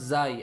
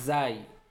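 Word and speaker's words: zai (زاي): Ra, and then this letter is pronounced zai. Actually, zai. I'd say that's pronounced. Okay, like I didn't make it up. There's no dot. That's a ra with a dot. It's a zai. Right, you can. Some people call it zal as well.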